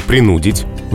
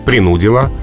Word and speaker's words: принудить: Правильно, принудить угу. и только так. И это ударение вообще никуда а, не переходит в этом глаголе. То есть в прошедшем времени принудил, принудила.